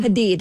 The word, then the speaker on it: Hadid: Hadid. – Hadid.